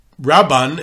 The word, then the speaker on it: Rabban: Number one, Mila'el almost never, doesn't really exist in Sephardi languages. So the name of the rabbi that you spoke about is Rabban, is pronounced Milara. There is a patach under the resh, Rabban.